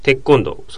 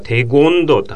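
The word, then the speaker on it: テコンドー: テッコンド、そのままですけど、テの後、ゴンっていうのが、そのままつながるから、テゴンドって発音したくなるんだけど、何々県、何々県っていう感じで、こう、いろんな種類があるので、そのゴンがノーになるわけですね。だから、テッコンドというふうに発音しますね。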